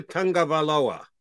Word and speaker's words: Tagovailoa: Like Tua Tagovailoa. There's no N in his name, but when you pronounce it, you can certainly hear the N.